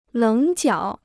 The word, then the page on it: 棱角: léng jiǎo